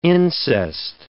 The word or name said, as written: incest